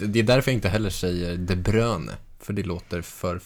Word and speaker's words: De Bruyne: Där jag alltså väljer att säga De Bruyne bara för att jag har hört i någon intervju att han säger De Bruyne.